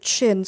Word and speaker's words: Chin: And we don't know if Chin, spelled C-H-I-N-N, is really our last name, because he used C-H-I-N, but Americans pronounce it Chun, or there's different intonations, so we think it's spelled C-H-I-N.